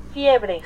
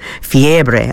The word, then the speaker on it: fiebre: And let's just make sure I'm pronouncing it right. Let's see, what does Google say? – Fiebre. – Fiebre. I was pronouncing it right.